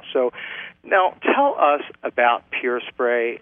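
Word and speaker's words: PUR Spray: And we pronounce that Pure Spray, P-U-R Spray? – That's correct. Thank you.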